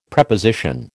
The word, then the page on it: Preposition: American English